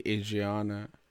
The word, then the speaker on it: Adrina: Before she gonna meet herself now, she say, my name is actually Adriana, but it's spelled Adrina.